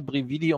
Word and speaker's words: Brividi: So, und jetzt sage ich zum Schluss noch einmal Brividi, um es noch einmal richtig ausgesprochen zu haben, und stelle mich wieder auf Stumm.